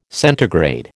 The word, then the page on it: centigrade: American English